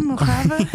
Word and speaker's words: Mojave: Die was eigenlijk 2 weken geleden al last minute aangekondigd om de tent, de Mojave tent, of hoe je dat ook mag uitspreken. – Ja, Mojave.